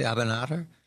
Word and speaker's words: Abinader: Another piece of good news, and it's rare, Dominican President Luis Abinader, do I say that correctly, Abinader? – Yes.